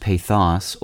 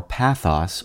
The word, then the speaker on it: pathos: Today's word is pathos, also pronounced pathos or pathos or pathos. It's spelled P-A-T-H-O-S.